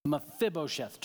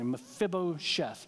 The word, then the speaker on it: Mephibosheth: One last illustration of this, David understood, in 2 Samuel chapter 9, David extended friendship to a guy named Mephibosheth. Mephibosheth. Took me 30 minutes to say that correctly. Mephibosheth.